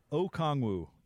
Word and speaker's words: Okongwu: Oyeka Okongwu.